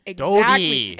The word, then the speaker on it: Doughty: Giordano isn't there because they need an LA King, and Drew Doughty's the only one. – Doty. It's Doty. Excuse you.